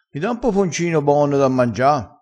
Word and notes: poponcino: dialettale

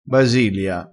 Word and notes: basilia: dialettale

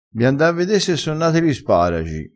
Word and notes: da quer di: dialettale

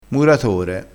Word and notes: muratore: dialettale

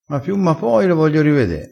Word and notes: ma piu ma poi: dialettale

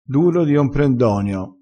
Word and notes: duro di omprendonio: dialettale

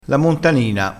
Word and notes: montanina: dialettale

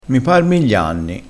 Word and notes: miglianni: dialettale

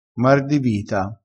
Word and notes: mar di vita: dialettale

